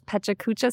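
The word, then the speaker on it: Pecha Kucha: And I was also really interested looking at your slides to see this Pachacucha style.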